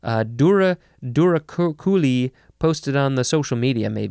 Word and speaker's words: douroucouli: Duraculi, I think that is how you pronounce it. It is spelled D-O-U-R-O-U-C-O-U-L-I. Duraculi.